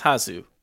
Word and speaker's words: Hasu: Jasu, I always mispronounce, because J in Spanish is pronounced as an H. And so is it Jasu or Hasu? – I think it's Hasu.